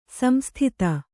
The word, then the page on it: ಸಂಸ್ತುತೆ: samstute